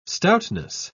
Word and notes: stoutness: British English

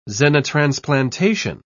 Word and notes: xenotransplantation: British English

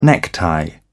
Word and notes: necktie: British English

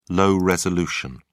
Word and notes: low-resolution: British English

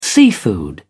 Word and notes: seafood: British English

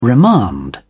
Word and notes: remand: British English

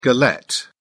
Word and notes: galette: British English

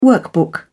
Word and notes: workbook: British English

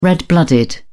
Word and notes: red blooded: British English

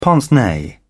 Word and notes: pince nez: British English

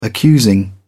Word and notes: accusing: British English

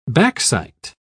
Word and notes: backsight: British English

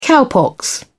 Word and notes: cowpox: British English

